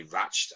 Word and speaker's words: Ratched: It's actually Ratched. I actually pronounced it wrong.